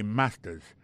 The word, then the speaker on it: master's: Regardez bien pour voir que l'accent est tombé sur le premier syllabe là. I got a master's.